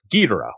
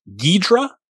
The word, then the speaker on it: Ghidorah: And Ghidorah is the other big one, which is also 3 syllables. Ghidra, 2 syllables. We have, I think, because of American translations or American dubs of the film would use 2. And some people think maybe that's because it sounds more like Hydra, Ghidra.